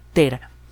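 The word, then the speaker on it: Thera: Un buen ejemplo es Tera, eh, bueno, el nombre en actualidad es Tira, en la pronunciación clásica es Tera.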